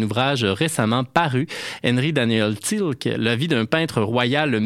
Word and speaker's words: Thielcke: Il s'appelle Henri-Daniel Tilke, T-H-I-E-L-C-K-E. Ça se prononce apparemment en français Tilke, même si c'est d'origine germanique.